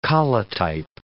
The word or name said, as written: collotype